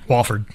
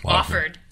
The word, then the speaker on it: Wofford: How do you say it? – Wofford. – Wofford. – Yep.